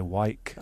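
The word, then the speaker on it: Wijk: So that W-I-J-K, I say Wyk.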